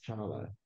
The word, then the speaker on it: Tchala: My first name is Olowonjo, and my last name is actually Chala. You don't pronounce the T. – Ah. – But it does have that T there, that T-C-H-A-L-A. The first name, last name is Chala.